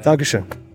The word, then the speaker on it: Dankeschön: Dankeschön. Oh no, I didn't say it right. – Oh, you said it right.